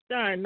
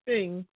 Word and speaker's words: Rosenstein: And he and Rod Rosenstein, it's pronounced Stein, not Sting,